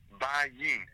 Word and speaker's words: Bajin: Bajin.